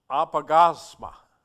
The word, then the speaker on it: apaugasma: The word is apagasma.